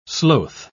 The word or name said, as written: sloth